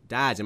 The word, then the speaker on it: Dodds: Dodds, am I saying that right? – I think so.